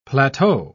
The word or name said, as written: plateau